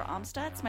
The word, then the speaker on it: Amstutz: Both of those places you can find me at Sandra Amstutz.